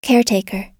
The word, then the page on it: caretaker: American English